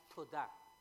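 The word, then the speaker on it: todá (תודה): La palabra, hay una palabra en hebreo que se pronuncia todá, y la traducción en español es muy, muy fácil, es gracias, pero en hebreo tiene un significado mucho más profundo.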